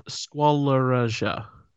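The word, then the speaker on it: Squaloraja: Squalariger. Like, you know, like... – It's not easy, is it? – It's squalor and then, like, azure.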